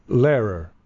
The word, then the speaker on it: Lehrer: And I know that he has the same sort of thing where it's H-R-E-R. – Yeah. – And I always said Tom Lehrer. And I heard him say his own name in a recording. – How does he say it? – Lehrer.